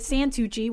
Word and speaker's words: Santucci: The only joke I could come up with is it rhymes with Santucci, which is my last name.